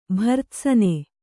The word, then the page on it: ಭರ್ತ್ಸನ: bhartsana